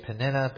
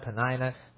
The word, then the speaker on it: Peninnah: And when the time was that Elkanah offered, he gave to Peninnah his wife, and I've heard that pronounced a couple ways, Peninnah, Peninnah, we'll just go with the latter, and to all her sons and her daughters portions.